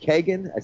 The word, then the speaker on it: Kagan: Barack Obama, Supreme Court nominee, is it Kagan? Is that how you pronounce her last name? – Yes, yes.